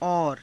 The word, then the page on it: aura: Hindi